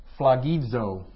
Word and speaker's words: phlogizo: And that is the word phlogizo. It's a fun one to say, huh? Phlogizo. It's phlogizo, long O, phlogizo. Again, remember that in the Greek, that's kind of a DZ sound. You can put a little D right in front of that, phlogizo. That's how you would pronounce that.